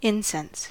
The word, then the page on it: incense: /ˈɪnsɛns/